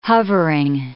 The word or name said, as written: hovering